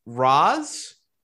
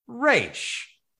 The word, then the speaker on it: Ra's: Ra's Ra's. I think people pronounce it both ways. We have certainly decided that it's going to be Ra's.